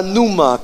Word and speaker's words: pneuma: Es la palabra numa que se escribe P-N-E-U-M-A.